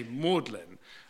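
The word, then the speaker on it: Magdalen: In Oxford, we don't pronounce the G, we say Magdalene.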